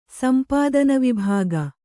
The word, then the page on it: ಸಂಪಾದನವಿಭಾಗ: sampādana vibhāga